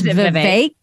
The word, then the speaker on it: Vivek: I just realized that I've been pronouncing it wrong this whole time. It's Vivek.